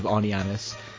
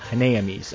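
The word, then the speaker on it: Honnêamise: When Teletoon was first promoting these films, they would consistently mispronounce Wings of Aniamis as Wings of Hanaamis, and unfortunately that seemed to have a very big impact on me, because I mispronounced it that way for this entire episode.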